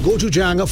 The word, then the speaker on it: gochujang: Introducing the new Bigfoot gochujang flavor. But before you can say it, you've got to learn it. It's easy, three syllables. Gochujang.